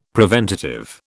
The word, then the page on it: preventative: British English